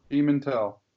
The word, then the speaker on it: Pimentel: But to rally, you know, down 2-0 in the bottom of the eighth, come back and score a six spot with, I can't pronounce his name, but Brandon Pimentel. Pimentel, right? – Pimentel.